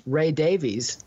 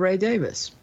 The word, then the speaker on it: Davies: It's just like many Kink fans in America think it's Ray Davies instead of Ray Davis, which I've learned the hard way.